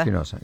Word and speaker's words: Espinosa: Espinosa.